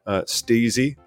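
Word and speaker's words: Stiiizy: Steezy, which is sometimes accidentally pronounced Stizzy, is also in our news.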